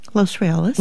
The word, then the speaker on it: Los Reales: How did you say it? – Los Reales. – Los Reales, okay.